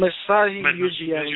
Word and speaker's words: Masai Ujiri: Masai Ujiri.